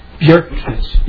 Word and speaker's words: Bjerkness: Yeah, my name is Odell, O-D-L-L, Bjorkness, B-J-E-R-K-N-E-S-S. And the J is pronounced Y, as in yes, just for future reference.